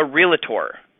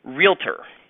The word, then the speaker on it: realtor: Realtor is two syllables. It's not three syllables. It is not a realtor. It's realtor.